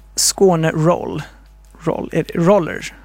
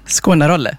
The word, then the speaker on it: Skåne Roller: Föreningen Skåne Roller kanske. – Skåne-rolle, ja. – Skåne-rolle, aha, du skulle uttala så.